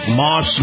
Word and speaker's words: Maus: This portion of the program brought to you by my very good friends at Moss Nissan. Now, Moss is spelled M-A-U-S.